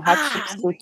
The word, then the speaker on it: Hatshepsut: Isso mesmo, Hatshepsut.